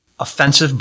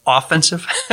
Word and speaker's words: offensive: But on the more sort of proactive, and I have to remember how to pronounce this, not offensive, but offensive.